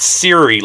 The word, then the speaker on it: Siri: However, I think that she pronounces it Siri, like on Apple.